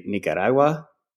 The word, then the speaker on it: Nicaragua: Nicaragua. That was okay. Yeah, good, Aaron. – Thanks. – If you want to say it, you know, the local way, you could say Nicaragua.